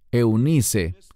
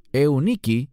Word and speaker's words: Eunice: Y tu madre, Eunice, que literalmente en griego es Euniki y significa la buena victoria.